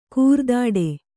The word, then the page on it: ಕೋರೆದಾಡೆ: Kannada